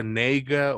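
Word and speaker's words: Negga: Es que, es que ella ha dicho, que se pronuncia Nega o, Nega, no sé, pero es así, es Ruth Nega, Andrew Holland, Alexander Skarsgård y Bill Camp.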